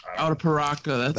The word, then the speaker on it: Arapiraca: Arapiraca. That's a hard word to say. I had to like do it phonetically. Brazilian Arapiraca. That's a tough one.